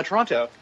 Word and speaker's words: Toronto: Good afternoon from Toronto. – Ah, yes. Why are you pronouncing the T? I was told you're not supposed to pronounce the T.